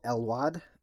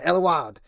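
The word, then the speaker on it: Éluard: After the war, he attended the University of Paris, eventually meeting an idol, Paul Elwad. That's the most American pronunciation. I can't talk right now. I'm not going to bother. – Spell it. – It's E with the accent over it, L-U-A-R-D. If you know your European poets and shit, you'll probably be like, yeah, Eluard. That's actually probably it's pronounced.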